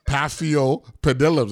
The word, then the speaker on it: Paphiopedilums: So, Paphiopedilums. That's six, right? Six syllables.